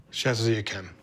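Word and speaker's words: Château d'Yquem: Chateau Yquem.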